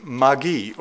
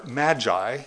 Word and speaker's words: magi: But it does call them, or at least Matthew calls them, magi, or magi, as we would pronounce that word.